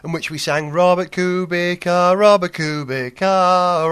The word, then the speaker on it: Kubica: Kubica has one of those little V symbols above the C, little accent that'll be, so it should sound like a TS, not a K. Kubica, then.